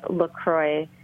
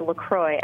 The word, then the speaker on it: LaCroix: Members who make it all the way through the Avoid Soda Challenge are earning themselves a free case of La Croix. And I did check on this. It is pronounced LaCroix and not LaCroix.